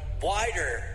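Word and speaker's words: WIDR: It's actually pronounced 89.1 Wider FM. – That's what I say. Wider FM.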